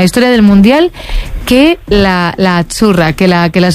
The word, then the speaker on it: Azzurra: Azzurra. – Azzurra. Mira, ¿ves? – Azzurra. Es que... Azzurra. Sí, como porque pizza. La Z no es la Z como es, decimos en catalán tan sonora, no.